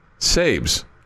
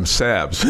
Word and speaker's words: Sabes: Saberhagen's nickname was um, Sabes, okay? But Billy Gardner always called him Sabs.